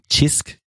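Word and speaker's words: Cisk: And I also totally know Chisk. That's how you say it, the Maltese beer.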